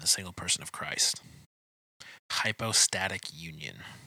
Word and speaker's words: hypostatic union: But those are the two main ones that came to my mind as when I thought about early church issues with this hypostatic union, if we can use a fancy theological term. Now I'm going to look that up to make sure I said it right. Hypostatic union. Yes.